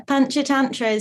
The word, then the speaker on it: Panchatantra: What is Panchatantra? Am I saying it correctly? – Yeah, Panchatantra is the most recent collection.